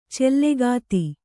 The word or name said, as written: ಚಲಗತಿ